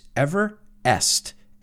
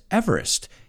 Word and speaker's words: Everest: it's Everest. So it's interesting because everybody says Everest.